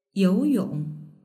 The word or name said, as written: youyong